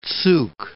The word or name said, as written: Zug